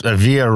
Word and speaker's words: Avila: Avila rhymes with tortilla, by the way, which are still baking in my system right now.